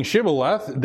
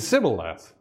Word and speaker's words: Shibboleth: And instead of saying Shibboleth, they said Sibboleth. They dropped the H.